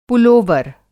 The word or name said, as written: पुलोवर